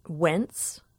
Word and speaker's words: Wentz: Well, last week I played a recording by a wooden flutist who was born in Pennsylvania, as it turns out, and I've been pronouncing his name wrong for years. It's Jed Wentz. And I've been saying Jed Wentz for a long time until I looked him up.